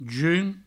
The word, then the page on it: junh: jun; Provençal